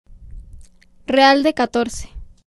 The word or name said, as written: Real de Catorce